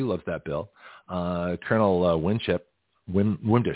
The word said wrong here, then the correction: Wimbish: excuse me. I'm going to get his name wrong for a while. – Wimbish. – Colonel Wimbish, who looks just like Alan West.